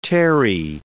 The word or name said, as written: terrae